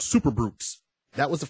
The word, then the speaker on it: Brutes: Now, Brutes spelled B R O O T as in Tom S. But it sounds like Brutes, B-R-U-T-E-S. That's how it's pronounced.